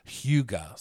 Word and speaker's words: Hygge: So it kind of looks like, in English, it would be like Hygge. So okay. – I've heard people say that, but that's not actually how you say it. It's Hygge. So think of like if you're going to IKEA and trying to pronounce all those names, it's very similar.